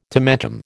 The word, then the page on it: tomentum: American English